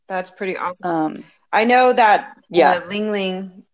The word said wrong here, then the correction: Ylang Ylang: did I say that right? Ling Ling? – Ylang Ylang, yeah.